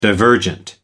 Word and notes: divergent: American English

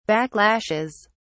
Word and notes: backlashes: British English

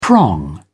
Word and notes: prong: British English

